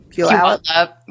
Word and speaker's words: Puyallup: I would like to go to I'm not going to say it right. – Puyallup. Yeah. – Puyallup.